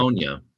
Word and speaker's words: Livonia: Is it Livonia? Is that how it's pronounced? – Yeah, Livonia.